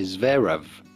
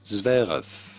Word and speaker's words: Zverev: The V sound at the end of Zverev sounds more like an F in German than a V. So do not say Zverev, but rather Zverev.